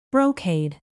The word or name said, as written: brocade